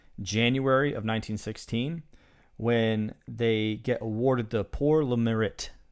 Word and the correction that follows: Pour le Mérite: That's terrible pronunciation. Pour le Mérite. It's actually, ironically, a French name for a German award.